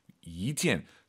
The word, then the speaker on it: 一件: 이件. 숫자 1이 이성으로 바뀌어야 돼요. 뒤에가 사성이니까 숫자 1은 이성입니다. 이件 이렇게 가야 됩니다.